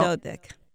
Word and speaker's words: Dodyk: That's right, Sonia so- Dodyk.